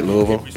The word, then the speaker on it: Louisville: Did I say it wrong? Is it Louisville? – Yeah, it's Louisville. You're good.